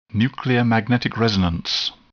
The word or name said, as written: nuclear magnetic resonance